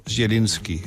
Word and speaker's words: Zielinski: Ihr Name ist nicht allzu häufig, aber es gibt einen anderen in Polen, der die Grundlage für Ihren Namen ist und der schreibt sich folgendermaßen: Zielinski. Spricht sich ein bisschen dann aus, etwa so Zielinski.